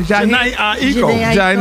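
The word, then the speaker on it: Jhené Aiko: Janae Aiko.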